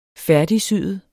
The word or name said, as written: færdigsyet